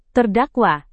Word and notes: terdakwa: indonesian

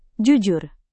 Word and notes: jujur: Indonesian

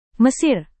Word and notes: mesir: Indonesian

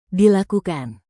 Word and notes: dilakukan: indonesian

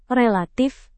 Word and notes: relatif: indonesian

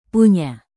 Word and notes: punya: indonesian

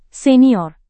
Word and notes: senior: Indonesian

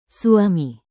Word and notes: suami: Indonesian

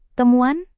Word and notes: temuan: indonesian

